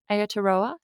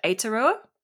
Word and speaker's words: Aotearoa: Aotearoa, is that how you say it? – I think it's closer to Aotearoa. – Aotearoa? – Yeah. Yeah. But they say it with that really cool lilt.